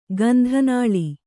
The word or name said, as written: ಗಂಧನಾಳಿ